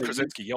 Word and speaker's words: Krasinski: John Krasinski, I think is his name. – Oh, John Krasinski.